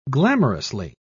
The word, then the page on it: glamorously: British English